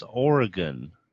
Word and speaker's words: Oregon: And Brent, now, Oregon? That's Oregon. It's whatever. A short O, not a long O.